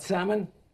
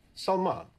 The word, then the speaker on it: Salman: It's not Salmon? – Salman.